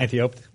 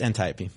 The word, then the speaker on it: Antiope: Antiope? – No, it's Antiope. – It's what? Antiope? – We all just yeah. learned. – It's all right. It's Greek. But yeah.